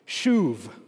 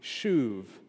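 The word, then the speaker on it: Shuv (שוב): Shuv. Shuv. Like, you could, phonetically, you'd spell it like S-H long U-V. Shuv.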